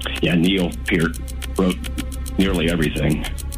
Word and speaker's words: Peart: It is pronounced Peart, not Pert.